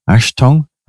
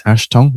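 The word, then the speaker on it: Achtung: Let me pronounce this correctly. Ashtong. I think it's Ashtong, which is a warning in the German.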